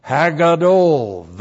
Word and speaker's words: Hagadol: Term number one, Hagadol. H-A-G-A-D, long O-L. Ha, that's the definite article. The great. So the day of the Lord is first of all described as Hagadol, the great day of the Lord.